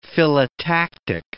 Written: phyllotactic

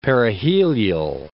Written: perihelial

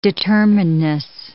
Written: determinedness

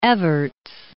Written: Evarts

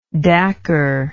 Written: dacker

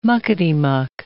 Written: muckety-muck

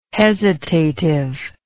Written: hesitative

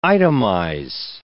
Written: itemize

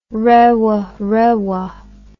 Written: rewarewa

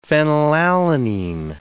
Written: phenylalanine

